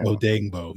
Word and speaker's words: Odeyingbo: Odeyingbo. – Yeah. Odeyingbo.